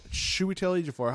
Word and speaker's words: Chiwetel Ejiofor: Chiwetel Ejiofor. That's a hard name to say. – That's how you pronounce it.